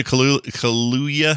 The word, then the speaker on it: Kaluuya: Daniel Kaluuya.